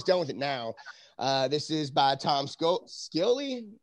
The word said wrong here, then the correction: Scioli: Somebody pronounce that speaks Scioli. – Okay, there we go. Scioli.